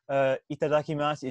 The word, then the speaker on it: itadakimasu: He was like, hey, to remember how to say itadakimasu, it's itadakimasu.